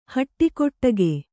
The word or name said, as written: ಹುಡುಗಾಟಿಕೆ